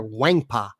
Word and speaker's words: Nwankpa: By the way, you don't pronounce the N. I learned how to pronounce that because I actually thought we were going to get him, and we didn't. It's Xavier Nwankpa.